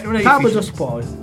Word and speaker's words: Trabzonspor: Prova a dirla, dai, non è Trabzonspor.